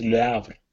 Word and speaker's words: Le Havre: This is the H is silent, so it's Le Havre.